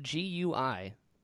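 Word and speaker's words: Gui (龟): It's it's spelled G U I, pronounced Gui, and it means turtle yeah, yeah.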